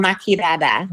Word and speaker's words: Maki Dada: Oh, Maki Dada. – Maki Dada.